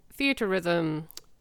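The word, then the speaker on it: Theatrhythm: And I'll be playing, and I know I pronounced this wrong, Theater Rhythm, because that comes out next week. – Sounds right to me.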